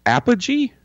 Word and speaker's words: Apogee: So, how did you say that? I would Apogee. Apogee, okay, which had created effects for Battlestar Galactica as well.